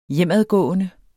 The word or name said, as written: hjemadgående